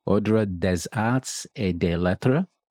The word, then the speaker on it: Ordre des Arts et des Lettres: The French Ministry of Culture made her a commander of the, and you're probably going to have to correct me on the pronunciation here, but I'll give it a go, Ordre des Arts et des Lettres. – That's close enough.